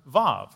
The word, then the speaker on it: Vav: This morning, we are in the Hebrew letter Vav. It starts with a W, but pronounced with a V as we see it translated for us in your text in Psalm 119.